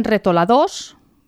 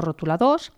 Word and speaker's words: retoladors: Dins d'aquest estoig hi posen retoladors, ho pronunciem amb la lletra E, que és tal com s'escriu, no rotuladors, i també hi porten un o dos llapis.